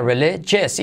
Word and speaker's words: religious: R, ra, ra, ra, rele. Letra clave L, le, Manuel. I como E, religious. Y luego GJ, religious. Y ojo el IOS, que no se dice religious, religious, sino religious. Religious. Había algunos problemas religiosos en la zona.